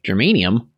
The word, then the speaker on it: germanium: His circuit consisted of a sliver of germanium with, did I say that right?